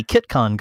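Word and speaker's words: CitCon: We're going to be at the KitCon conference. Note the pronunciation. It's C-I-T-C-O-N.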